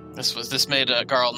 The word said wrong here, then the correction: Jarl: Garl or Jarl? – Jarl. – Yeah. – Is Jarl? I pronounced it wrong, yeah.